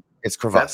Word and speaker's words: crevasse: Is crevasse the actual pronunciation? – It's crevasse. – That's it. It's crevasse.